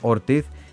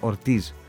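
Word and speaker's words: Ortiz: Ο τεράστιος Χωσέ Πικουλίν Ορτίθ. Εγώ βέβαια θα διορθώσω όλους αυτούς το 99% επειδή είναι Πορτορικανός, εκεί διαβάζεται ως Ορτίζ.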